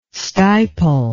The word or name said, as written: stipel